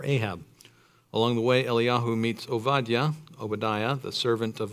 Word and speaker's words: Eliyahu: Elijah, or Eliyahu, as it says in the text, the Hebrew way of pronouncing his name.